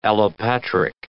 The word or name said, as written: allopatric